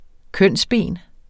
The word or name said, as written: kønsben